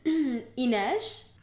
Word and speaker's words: Inej: Inej? – Okay.